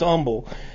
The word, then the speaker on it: Humble: it said that the name is Humble, but in southern states they pronounce it Humble, like you would you wouldn't pronounce the H in honest.